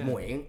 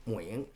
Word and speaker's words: Nguyen: Is it Nguyen or Nguyen? – It's Nguyen. It's pronounced Nguyen. Okay. But I remember like classmates in school, teachers always say Nguyen.